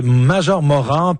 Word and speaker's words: Major Morand: Oui, Major Morand,